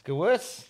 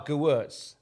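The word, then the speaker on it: Gewirtz: Gewurz. Yeah, I got his book, and one of the things it starts up was how to pronounce his name. – Ah, Gewurz. – Yeah. As in, he's the worst.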